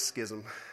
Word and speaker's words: Schism: I don't know how you pronounce that. Schism. it's Schism.